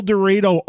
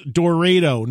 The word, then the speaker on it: El Dorado: I just came from El Dorado, Arkansas. I know what you're saying. Where? And yes, I have confirmed it is pronounced Dorado, not Dorado. El Dorado, Arkansas.